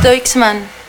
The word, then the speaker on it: Deichmann: Es Deichmann. – Nueva temporada primavera-verano de Deichmann. No es fácil decirlo, pero qué fácil es encontrar al mejor precio y los mejores zapatos. Deichmann, mucho por andar.